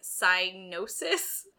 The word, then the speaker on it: Psygnosis: It's spelled P-S-Y-G-N-O-S-I-S. There are too many letters that are not supposed to be pronounced in that word. – I think it's Italian, so it's Psygnosis, like gnocchi.